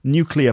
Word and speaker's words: nuclear: There's also the word nuclear, like nuclear weapons, nuclear power, nuclear, which is pronounced nuclear. But lots of people will say nuclear weapons or nuclear power. And that's wrong. It should be nuclear, like clear pronunciation, nuclear.